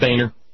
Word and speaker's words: Boehner: And I said, Boehner. And he's proud to talk about his German heritage.